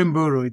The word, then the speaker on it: Mburu: It's Jeffrey Mburu. It's a soft M.